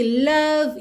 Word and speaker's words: love: Atenção aqui, love you. Lembra que o é final de love, não é pronunciado, então você já junta o V no you.